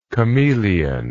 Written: chamaeleon

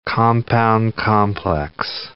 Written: compound-complex

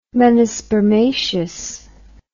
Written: menispermaceous

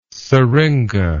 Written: seringa

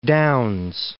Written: Downs

